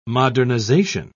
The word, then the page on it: modernisation: British English